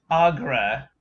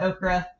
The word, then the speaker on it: Aughra: It's Agra. A-U-G-H-R-A. – Yeah, it's not Okra.